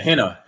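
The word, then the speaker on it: hentai: If you read the headline, it says Nintendo eShop's front page currently features a Henta game. Did I say that correctly? – Yeah, Henta.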